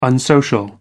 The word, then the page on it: unsocial: American English